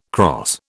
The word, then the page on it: crosse: British English